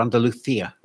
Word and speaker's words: Andalucia: Andalucia Masters, or Andalucia, wouldn't it be, if you were saying it properly? – Yes.